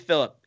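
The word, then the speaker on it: Filip: That's how you say his name, right? Flip Ingebrigtsen, one of the Ingebrigtsen brothers. – I think it's Philip. I don't know. – Yep. Oh, it is Philip. It's Philip Ingebrigtsen.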